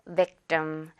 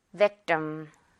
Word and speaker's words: victim: victim 아니고 victim. 굉장히 된소리 많이 신경 써서 가볼게요. victim.